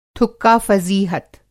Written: थुक्का-फ़ज़ीहत

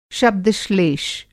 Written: शब्द-श्लेष

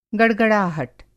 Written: गड़गड़ाहट

